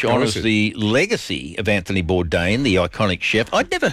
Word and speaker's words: Bourdain: Is it Bourdain? Anthony Bourdain? Is that how you say his name? – No, oh, I'd say Bourdain. Hudson's got a theory. You reckon it Bourdain? – Yeah, it's Bourdain.